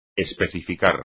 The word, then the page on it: especificar: espagnol